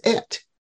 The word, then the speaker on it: et: The short E is like eh in the word pet, and the word I gave you was et, E-T.